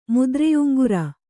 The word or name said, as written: ಮುದ್ರೆಯುಂಗುರ